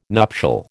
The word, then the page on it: nuptial: American English